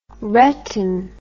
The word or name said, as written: retin